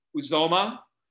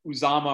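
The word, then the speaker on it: Uzomah: My props last week with the Jets, no good, except for the Bengals tight end, whose name I did not pronounce correctly last week, and I probably won't do it again. Uzoma. I think I called him Uzama or whatever I called him last week.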